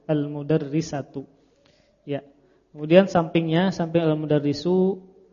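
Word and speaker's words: Al-mudarrisu (المدرّس): Yang benar bacanya gimana? Ro-nya pakai tasydid. Al Al-mudarrisu.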